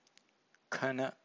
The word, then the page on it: khana: Pali